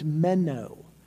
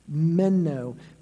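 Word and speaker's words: meno (μένω): In the original Greek language, the word is pronounced for that word, it's meno. Menno. Menno means to, and like a minnow that you catch to catch bigger fish, right? Sounds something like that.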